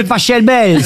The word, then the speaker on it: Pachelbel: Canelloni, c'est Pachelbel. – S'il est allemand, ça se dit Pachelbel. – Exactement, ouais. Ça pourrait se dire éventuellement Pachelbel, ouais.